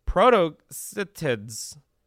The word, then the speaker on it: protocetids: This particular ancient species was previously unknown to experts, but it's part of a group of semi-aquatic whales called protocetids, and that is the pronunciation, you're welcome, which existed during the Eocene period.